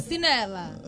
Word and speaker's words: Pulcinella: Pulcinella. – Ahí lo pronunció bien.